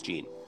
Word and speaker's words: Jeanne: But I think it's Jean. J-E-A-N-N-E. – Yeah, that sounds like Jean to me.